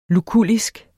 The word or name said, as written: lukullisk